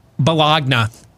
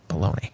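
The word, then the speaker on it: bologna: You know, he said that in the rehearsal, and then someone pulled him aside and said, sir, I know you've never eaten one of these sandwiches, but it's actually pronounced baloney.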